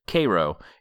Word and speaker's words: Cairo: It's spelled like the Egyptian city, but on the page it's spelled K-A-Y-R-O, which is good to have that pronunciation, because I don't know that I would have made that if it was spelled out like Cairo.